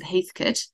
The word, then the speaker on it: Heathcote: And then actually my favorite region for actual drinking of wine is directly north of Melbourne, and that's because it is an area called Heathcote, spelt Heathcote, but we pronounce it Heathcote, and it rivals the Barossa Valley for really big, bold, dark reds.